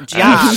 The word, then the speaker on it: giallo: Well, we brought up the word giallo a couple times, and Cam, you're actually the one who taught me how to say the word giallo. You say it like you're saying ciao, giallo.